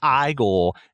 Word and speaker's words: Igor: It's like, He's like, oh, you must be Igor. No, it's pronounced Igor.